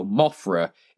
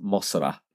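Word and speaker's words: Mosura: So Mothra in Japan is pronounced Mosra. It's Mosra. What does Mosra sound like? It sounds like Mothra.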